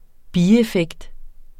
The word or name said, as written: bieffekt